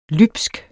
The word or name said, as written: lybsk